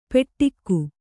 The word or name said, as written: ಪೆಟ್ಟಿಕ್ಕು